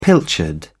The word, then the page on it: pilchard: British English